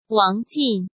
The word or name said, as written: Wang Jin